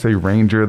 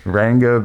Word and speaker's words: Ranga: and he could be found on Twitter at Ranger the yeah. Trainer, but it's really Ranga the Trainer. Mm-hmm. It's a hard G, that's what he always tells me, but when I say always, once. Ranga the Trainer, go out and follow him on Twitter.